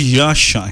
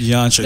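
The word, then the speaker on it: Jasha: The lead vocalist is Yasha. His name is Yasha. – There's no Michael inside. – Spelled with a J.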